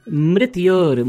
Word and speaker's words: mrityor: Mitaror mushki. – Mitaror mushki. No, it is mrityor mukshya. Mrityor. Yes. Mrityor.